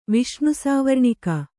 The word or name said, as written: ವಿಷ್ಣುಸಾವರ್ಣಿಕ